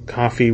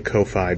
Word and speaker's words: Ko-fi: Um, also, our uh, Patreon, coffee, which I always want to say Ko-Fi because phonetically, it should be that.